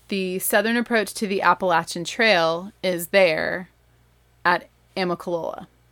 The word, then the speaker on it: Appalachian: And yes, the correct way to say this is Appalachian. It is not Appalachian. It is not Appalachian. It's Appalachian.